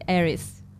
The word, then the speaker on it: Eris: It's like Eros, but with E, Eris.